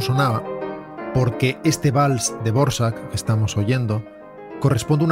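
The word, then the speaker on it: Dvořák: No, sí, es la misma persona, pero se pronuncia Dvorak.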